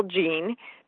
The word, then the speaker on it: Jean: But often, when I'm saying all three names together, I reduce my first name to one syllable, Jean, because my other two names, Zulik and Farulo, are already kind of long and a little bit tricky.